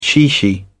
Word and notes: chichi: British English

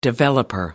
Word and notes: developer: American English

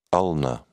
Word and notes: ulnar: British English

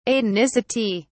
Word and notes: atonicity: British English

